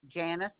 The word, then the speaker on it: Janis: Janice. I spell that J-A-N-I-S.